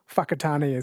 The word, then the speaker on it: Whakatane: I think the WH is pronounced F, like Whakatane is WH, Whanganui WH instead of F.